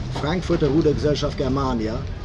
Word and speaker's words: Frankfurter Rudergesellschaft Germania: Everyone that's listening, the formal pronunciation is Frankfurter Rudergesellschaft Germania von 1869 eingetragene Verein,